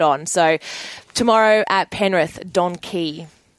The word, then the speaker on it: Donkee: and she has Don Key. So it's spelt D-O-N-K-E-E, and it's racing tomorrow at Penrith.